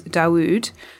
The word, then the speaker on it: Dauwd: I've been listening to a new release by a Welsh producer called Dawood. Not to be confused with Darude. It's spelled D-A-U-W-D.